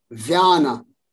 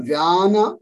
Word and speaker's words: vyana: So the pronunciation is prana, apana, samana, udana, vyana. Not apana, apana. – Apana, okay. – So it's prana, apana, vyana, udana, samana.